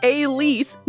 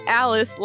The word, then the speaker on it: Alice: Pronounced Elise, not Alice, like it looks.